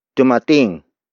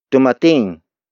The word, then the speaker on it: dumating: Word for word, it's dumating siya dito kanina. Dumating siya dito kanina.